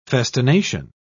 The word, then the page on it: festination: British English